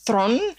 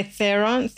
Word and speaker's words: Theron: I think her surname is pronounced Thron, but we say Theron. But it's actually Thron, so Charlize Thron, I want to say it properly, because she's South African and that's how they pronounce it.